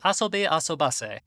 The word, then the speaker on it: Asobi Asobase: Ladies and gentlemen, we are back after watching three whole episodes of Asobi Asobase, which is the correct pronunciation after looking at the hiragana.